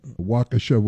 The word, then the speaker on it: Waukesha: You grew up in Waukesha, Wisconsin, right? Am I pronouncing that right? – Yeah, it's good. A lot of people don't get it right the first time.